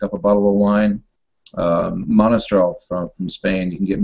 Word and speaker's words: Monastrell: Well, on that note, everybody out there, when you buy these books, go to Amazon or Montrestrel directly. How do you say it? – Monastrell. – Monastrell. I knew I was saying that wrong.